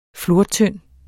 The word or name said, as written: flortynd